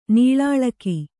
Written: ನೀಳಾಳಕಿ

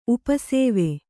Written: ಉಪಸೇವೆ

